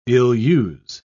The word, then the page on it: ill-use: British English